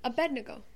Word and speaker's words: Abednego: How do you pronounce it? – Abednego.